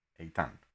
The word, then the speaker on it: Eitan: I just want it to become famous so that I can be like Titan without the T. Eitan. That's the way to spell it.